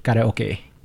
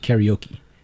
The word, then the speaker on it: karaoke: It's anime and it's karaoke, not karaoke.